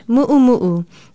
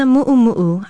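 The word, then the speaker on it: mu'umu'u (muʻumuʻu): It is spelled M-U-O-K-I-N-A-U, M-U-O-K-I-N-A-U, and is pronounced mu'umu'u. Don't confuse it with the word mumu, a thud-like sound. The dress is a mu'umu'u.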